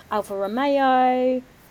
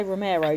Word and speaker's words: Alfa Romeo: Did I say that right? I didn't say Romero, did I? – No. – No. – No, you didn't.